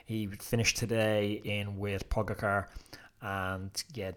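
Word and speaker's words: Pogačar: We've Pogacar, who I'm close to changing my pronunciation on him, because Pukacha seems to be the interpretation that everyone's going with.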